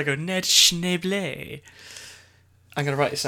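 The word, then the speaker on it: Schneebly: S-H-N-E-E-B-L-E-E. – No. – Go on. – It's S-C-H-N-E-E-B-L-Y. – It's pronounced Schneble. – Schneble. – Okay. – Well, Jack Black might pronounce it Schneble, but it's...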